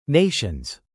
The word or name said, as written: nations